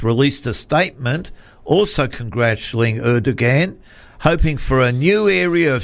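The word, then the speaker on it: Erdoğan: Erdogan. Like Recep. That's his first name. Richard. Recep. – Yeah. – Erdogan.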